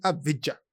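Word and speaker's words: Avdija: O Rafa me mandou um áudio de um ele é amigo de um israelense que trabalha com basquete e perguntou para ele como é que fala, e o cara mandou o áudio para ele, Lucas. É parecido com o jeito que a gente fala aqui, Avidja.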